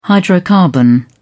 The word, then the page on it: hydrocarbon: British English